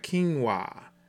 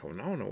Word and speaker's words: quinoa: So acai, A-C-A-I, so if you never knew how to pronounce that, now you know. It's just like quinoa. It doesn't look like it pronounces. I know somebody asked me one day, they said, have you ever had some of that quinona? I ain't never, and I'm like, quinona, what is that?